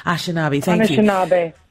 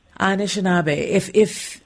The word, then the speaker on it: Anishinaabe: Anishinaabe. – Anishinaabe.